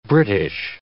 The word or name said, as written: British